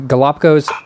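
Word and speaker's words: Galápagos: So you have a new book out, the Galapagos Island.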